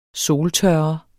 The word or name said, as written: soltørre